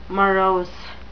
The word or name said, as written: morose